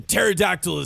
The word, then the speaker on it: pterodactyl: But for the layman, pterodactyl is spelt with a P and then a T. The P is silent.